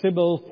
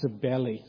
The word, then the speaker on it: Cybele: Now, Cybele for short, that we would say today, but it's pronounced Cybele.